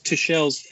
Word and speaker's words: Tuchel: Thomas Tuchel, I've been taught that's the right way to say it.